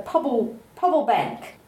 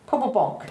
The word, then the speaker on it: Pobble Bonk: So Pobble Bank. Pobble Bonk. – Pobble Bonk. Pobble Bonk. If you say it like this, Pobble Bonk, because that's the sound that the frog makes.